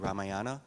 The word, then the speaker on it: Ramayana: Ramayana. – The Ramayana, I guess, is how you say it.